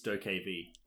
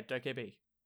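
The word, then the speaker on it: DokeV: It actually says the pronunciation in the description. – Oh, really? – I think it's Doke B. – Oh, okay. – Wow, so it does. Yeah, Doke B.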